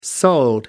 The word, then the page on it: sold: American English